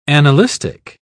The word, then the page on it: annalistic: British English